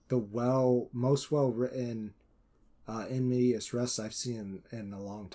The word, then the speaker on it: in medias res: I don't want to give away too many details about it, but it is a story told with in medias res. I said it wrong. Sure I did. – No, I think that's right.